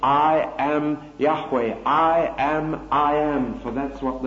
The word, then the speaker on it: Yahweh: J-H-V-H, pronounced Y-H-W-H, and probably pronounced Yahweh or something like that, certainly never pronounced Jehovah.